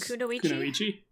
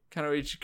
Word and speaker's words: Kunoichi: Kunoichi. Kunoichi. Kunoichi.